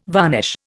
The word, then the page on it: varnish: Indian